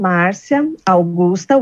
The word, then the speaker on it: Marcia Augusta: So my origin ID, is my name, Marcia Augusta, which is my middle name, and all written together, okay?